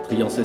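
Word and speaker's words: Triancey: Triancet s'écrit Triant comme dans Triangle, et C-E-Y.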